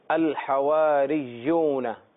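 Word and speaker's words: الحواريون: اذ قال الحواریون. حواری یہ مفرد ہے اور اس کی جو جمع ہے یہاں اس پوزیشن میں حواریون یوں ہم پڑھیں گے کیونکہ یا مشدد ہے. اذ قال الحواریون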